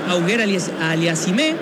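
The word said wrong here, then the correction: Auger-Aliassime: Es Oyer Aliassime, el canadiense. Félix Oyer Aliassime.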